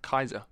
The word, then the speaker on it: Caesar: Oh, or apparently his name is supposed to be pronounced Kaiser. – Kaiser? – Yeah, Julius Kaiser, apparently, because they don't have like a C like we have it. – Oh, really? – So Kaiser, the C is supposed to be pronounced as a K.